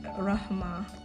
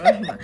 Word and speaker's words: Rahma: It is, it's Rahma. Like the actual pronunciation is Rahma.